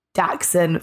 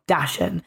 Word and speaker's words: Dachshund: And Lena has very helpfully said Dachshund phonetically, so D-A-X-E-N, not Dachshund.